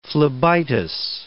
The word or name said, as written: phlebitis